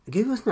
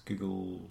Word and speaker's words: googol: Google's not a number, is it? It's a Googleplex. – It's Google. – Googleplex? I'm pretty sure. – No, it's a Google. – No. – But it's spelled G-O-O-G-O-L.